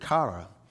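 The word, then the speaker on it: kara (קרא): Well, it's interesting that the word cry here in verse 1 is this Hebrew word, kara. It's strong 7121. If you take notes, it's Q-A-R-A, but it's pronounced K-A-W-R-O-W.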